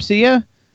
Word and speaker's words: Sia: Is that how you pronounce her name, Sia? – Yeah.